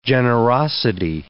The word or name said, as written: generosity